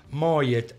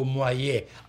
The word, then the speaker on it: Moyet: Proprio nel lontano 1982 l'ex eh, Depeche Mode, l'esilo di Depeche Mode, Vince Clark e l'eclettica cantante Alison Moyet o Moyet, a seconda che si voglia pronunciarla all'inglese o alla francese, eh, insieme fondarono, fondarono gli Yazoo e, pu, pu, e pubblicarono il Don't Go.